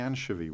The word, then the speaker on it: anchovy: I remember mispronouncing anchovy once. I thought you pronounce it like anchor because I'd never seen an anchovy.